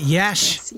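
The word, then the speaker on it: Iași: Is it Yash? – Yash. Like, rhymes with hash.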